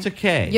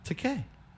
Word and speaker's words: Takei: Takei. – You know, is it Takei? – Takei.